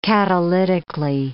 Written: catalytically

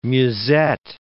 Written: musette

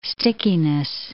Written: stickiness

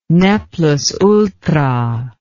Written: ne plus ultra

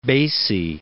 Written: Basie

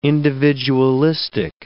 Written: individualistic

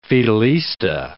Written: Fidelista

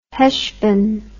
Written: Hesvan

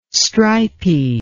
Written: stripy

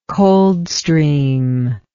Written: Coldstream

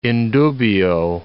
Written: in dubio

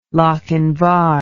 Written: lochinvar

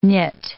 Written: knit

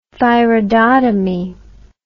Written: thyroidotomy

